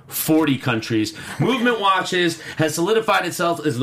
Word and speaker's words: MVMT: But I do trust my movement watch. Movement watches... pronounced very coolly, very few vowels used in that.